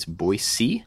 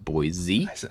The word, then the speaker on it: Boise: Yeah, so I just got back from Boise, which I learned very quickly. It's Boise, not Boise.